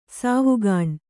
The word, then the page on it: ಸಾವುಗಾಣ್: sāvugāṇ